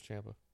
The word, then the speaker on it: Ciampa: Gargano, to say it Midwestern, versus Ciampa. I always mispronounce it, so I'm trying. – Tommaso Ciampa, but it is Ciampa.